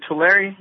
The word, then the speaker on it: Tulare: Yes, Tulare.